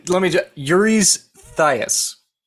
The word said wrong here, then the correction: Eurystheus: No, there's no thigh in there that I know of.